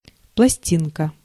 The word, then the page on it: disque: /disk/